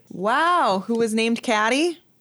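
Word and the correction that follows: Cady: Katie. – It's pronounced Katie. – It's pronounced Katie. – Caddy. – C-A-D-Y, Katie.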